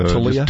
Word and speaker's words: Taulia: How do you pronounce his Taulia. name? It's, Taulia. It, it's easier than it looks. – Uh, Taulia. – Taulia. – Just yeah. With like with Tua, it looks like Tua, but Taulia, the U kind of throws you a little bit.